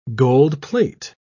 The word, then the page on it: gold-plate: British English